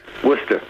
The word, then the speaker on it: Worcester: Worcester, 12 miles west of the Welsh border.